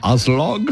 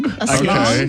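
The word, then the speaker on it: Aslaug: It's A-S-L-A-U-G. How would you say that? – Aslog? – Aslog. Okay. All right.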